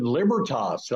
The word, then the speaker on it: Libertas: And he started this new organization, Libertas.